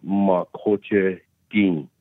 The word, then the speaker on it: Makoche Gi: The word Makoche means land. Gi, with a nasalized N, similar to the French language, I would say, which means the land. Gi is the, and Makoche is land. And, of course, the Lakota translate, when you translate into English, it's usually backwards, but it's pronounced Makoche Gi.